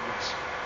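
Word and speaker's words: Woest: J-O-H-N W-O-E-S-T. That's pronounced West.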